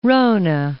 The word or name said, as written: Ronne Ice Shelf